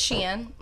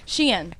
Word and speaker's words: Shein: Okay, so it's Shein. Like she in her Stagecoach era. – Oh, okay. – I'm just kidding. Shein.